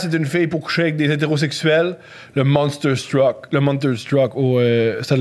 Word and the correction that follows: Monster Truck: Ça, je l'ai pas réussi à le prononcer. – L'événement Monster Truck.